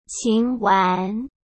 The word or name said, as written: Qin Wan